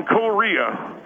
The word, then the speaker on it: Korea: military in Korea. – In Korea? – That's how my father pronounced it, and with respect to him, that's how I pronounce it.